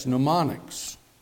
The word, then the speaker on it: mnemonics: It is M, which is silent, N-E-M-O-N-I-C-S, mnemonics.